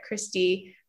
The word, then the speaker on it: Kristi: So you can follow me there at Kristi Ray of Sunshine.